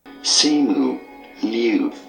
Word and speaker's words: Simu Liu: Simu Liu.